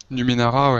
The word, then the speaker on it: Numenera: Numenera, Numerena. – Numenera, ouais. – Ouais, j'y étais presque.